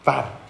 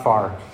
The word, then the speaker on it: Pharr: Pharr. That's how you say it in Spanish. You have to roll the R. Pharr. Not far. Pharr. Oh, and we're so misunderstood.